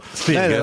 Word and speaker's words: Spielberg: Spielberg, la pronuncia è perfetta, si può rifare.